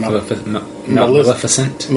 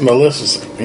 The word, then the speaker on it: Maleficent: Maleficent. – Maleficent, yeah.